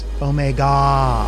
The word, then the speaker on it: Omega: Dennis, you mispronounced it. The name of the rocket is Omega.